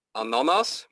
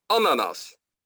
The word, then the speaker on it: ananas: Of niet ananas, maar ananas. Dus dat is de klemtoon.